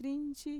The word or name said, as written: trinchi